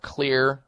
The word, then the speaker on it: Xlear: But you can try something called Clear Sinus Care Spray. Clear Sinus Care Spray. It's a xylitol form of nasal spray that's very effective. Clear is spelled X-L-E-A-R.